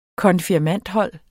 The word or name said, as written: konfirmandhold